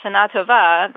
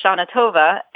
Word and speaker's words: Shana Tova: Well, there's also an interesting influence from English here, because English stresses most of its word on the penultimate syllable. And Hebrew, modern Hebrew, stresses most of the words on the ultimate syllable, the last syllable. Yes. So, Shana Tova. – Shana Tova versus Shana Tova. And Shana Tova fits in better to the sound structure of English.